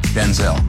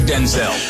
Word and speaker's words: Denzel: My name's not pronounced Denzel. It's pronounced Denzel. My father's Denzel Hayes Washington Sr. My mother would say, Denzel, we both show up. She said, from now on, you're Denzel.